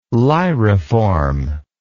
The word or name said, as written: lyriform